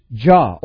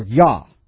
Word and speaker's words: Yah: His name is Jah, or Yah, as it's pronounced.